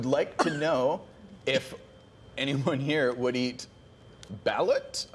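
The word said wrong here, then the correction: balut: I'm not sure how to pronounce that. – Oh, balot. – Fertilized duck eggs.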